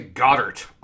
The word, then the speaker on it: Goedert: But that's okay because Dallas Goedert, is that how that's pronounced? – I think they say Goddard on television, which doesn't make a whole lot of sense.